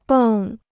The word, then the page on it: 蹦: bèng